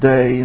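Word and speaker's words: day (δέ): In verse 18 you have a little word called pronounced men, and 19 a little word pronounced day, and when you put those two together, it always translates on the one hand, but on the other hand.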